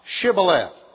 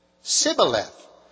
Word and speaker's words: Shibboleth: If he said nay, then said they unto him, and this is my text verse for the morning, say now, Shibboleth. And he said, Sibboleth, for he could not frame to pronounce it right.